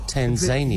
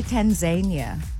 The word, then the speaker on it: Tanzania: Which emphasis do we put the syllable? – I say Tanzania. – Yeah. I say what feels good in the moment. – Exactly. I can change. Whatever yes. my mood feels like. I've said both.